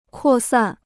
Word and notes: 扩散: kuò sàn

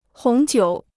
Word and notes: 红酒: hóng jiǔ

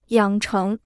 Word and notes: 养成: yǎng chéng

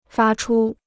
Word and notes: 发出: fā chū

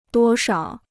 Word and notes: 多少: duō shao

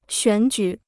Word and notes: 选举: xuǎn jǔ

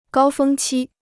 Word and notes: 高峰期: gāo fēng qī

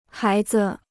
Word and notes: 孩子: hái zi

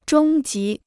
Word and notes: 中级: zhōng jí

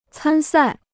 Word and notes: 参赛: cān sài

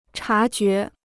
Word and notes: 察觉: chá jué

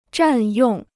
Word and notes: 占用: zhàn yòng

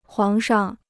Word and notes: 皇上: huáng shang

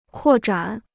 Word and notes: 扩展: kuò zhǎn

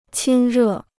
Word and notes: 亲热: qīn rè

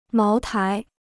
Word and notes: 茅台: máo tái

